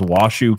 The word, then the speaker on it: Washoe: So this is coming out of, I think it's pronounced Washoe County, which is really weird. – Washoe? – It's W-A-S-H-O-E. – Washoe. Yeah.